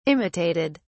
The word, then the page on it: imitated: British English